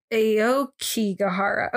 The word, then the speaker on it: Aokigahara: Okigara. I've heard it pronounced literally like 30 ways. – Yeah. I thought it was Aokigahara. – Aokigahara. Okay. So one of the news things I watched was Aokigahara.